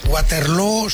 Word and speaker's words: Waterloo: Pues no lo es, desde luego, pero ABBA ha hecho mucho daño en la pronunciación de Waterloo, que se pronuncia igual que Feijóo.